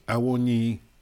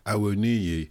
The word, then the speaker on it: Awoniyi: Shocking and embarrassing, but I think I'll have to accept that you pronounced Awoniyi correctly, whereas my anglicised version of Awoniyi just doesn't cut it, and I'm embarrassed.